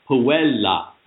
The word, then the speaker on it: puella: But, of course, in Latin, you never – the only time the last syllable is accented is in a one-syllable word. So it's to give it an ecclesiastical Latin, it's puella, puelle, puelle, puellam, puella, puelle, puellarum, puellis, puellas, puellis.